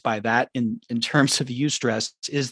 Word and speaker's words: eustress: But I want to make a point of mentioning this term that you may or may not be familiar with called eustress, which is spelled E-U-S-T-R-E-S-S. So it's stress with E-U at the beginning.